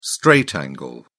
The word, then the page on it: straight angle: British English